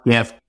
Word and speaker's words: GIF: And it's crazy, but at the time, Steve would take all comers in the fight that it was pronounced GIF. Now, he's obviously wrong. It's obviously pronounced GIF.